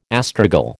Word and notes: astragal: American English

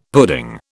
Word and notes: pudding: British English

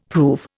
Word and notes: prove: Indian

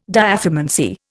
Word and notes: diathermancy: Indian

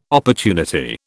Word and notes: opportunity: British English

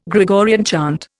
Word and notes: Gregorian chant: Indian

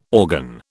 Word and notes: organ: British English

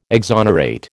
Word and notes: exonerate: American English

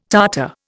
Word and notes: Tartar: Indian

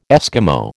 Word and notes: Eskimo: American English

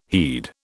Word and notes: heed: British English

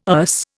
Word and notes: Erse: Indian

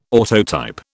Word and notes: autotype: British English

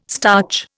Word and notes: starch: Indian

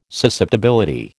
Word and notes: susceptibility: American English

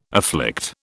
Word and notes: afflict: British English

